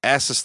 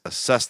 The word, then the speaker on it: assess: Because the girl says, "Ass us the window." And he goes, it's assess the window. You put the wrong emphasis on the wrong syllable.